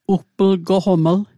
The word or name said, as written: ugplgoqoml